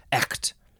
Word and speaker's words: echt: The word is spelled E-C-H-T. The pronunciation says E-K-T with a horizontal line. So how do you say that differently? I gotta go check the pronunciation thing. I don't really want to do that. But it's pretty much just like ect. Echt. Echt. Yeah, it probably has a little bit of a ch sound in there.